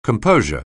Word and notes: composure: anglais

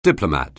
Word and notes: diplomat: anglais

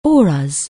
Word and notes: aura: anglais

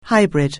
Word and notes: hybrid: anglais

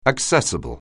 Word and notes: accessible: anglais

